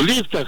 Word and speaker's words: лифтах: Абсолютно во всех словарях зафиксирован единственно верный вариант. И он с ударением на первый слог, поэтому правильно говорить еще раз как? – В лифтах.